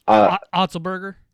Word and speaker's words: Otzelberger: Otzelberger.